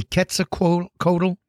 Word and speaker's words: Quetzalcoatl: The reason why I don't say Quetzalcoatl, I say Quetzalcoatl. A friend of mine is a language specialist, a linguist, and she studies the Aztec language. And she said it's pronounced Quetzalcoatl. The L is silent, Quetzalcoatl.